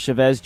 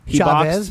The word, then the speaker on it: Chavez: Julio Cesar Chavez Jr. Chavez, but that's fine. – Chavez. – It's ethnic, so he had no chance.